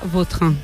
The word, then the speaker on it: Vautrin: Es que a mí me suena mejor Bauterín. Es como... – Da más fuerza, ¿no? – Más fuerza, más pronunciación. – Elegante. – La original francesa, Vautrin.